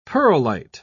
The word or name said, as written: perlite